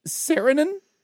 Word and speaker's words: Saarinen: This is the nationality of arch designer Eero Saarinen. S-A-A-R-I-N-E-N. Oh, and there's a pronunciation as well. Saarinen.